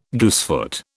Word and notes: goosefoot: British English

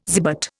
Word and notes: zibet: Indian

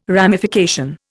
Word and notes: ramification: Indian

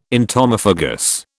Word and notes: entomophagous: British English

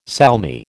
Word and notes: salmi: American English

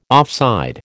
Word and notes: offside: American English

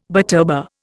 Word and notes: butterbur: Indian

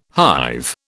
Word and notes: hive: British English

